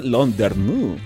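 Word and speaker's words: Landerneau: Ahora sí lo busqué, ¿cómo se pronuncia? Es Londerneau.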